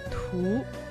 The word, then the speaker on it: 图: Слово «тху» произносится во втором тоне.